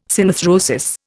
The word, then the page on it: synarthrosis: Indian